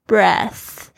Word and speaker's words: breaths: Breaths 这个特别注意一下，它是从这个 breathe 呼吸这个动词变来的名词 breath，它加上 s 的时候呢，可以直接跳过这个 th 的发音，直接发 s 这个 s 的声音 breath。